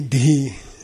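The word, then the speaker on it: dhih: Okay, all of Manjushri's wisdom you can put into one sound, which is dhi, D-H-I-H, D-H-I-H, dhi, dhi. Okay, say dhi. It's not just d. Okay, it's D-H-I, dhi. Okay, dhi, and it actually ends with an h, which is called a fricative or rough breathing in linguistics.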